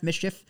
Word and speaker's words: MSCHF: M-S-C-H-F, Mischief?